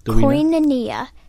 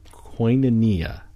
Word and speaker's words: koinonia: Koinonia. – Koinonia. – Koinonia. It's spelled K-O-I-N-O-N-I-A, but I didn't know that.